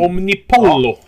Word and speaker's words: Omnipollo: Och så gav du mig den här, ja hur uttalar man det, Omnip Pol. Omnipollo. Ja, Omnipollo.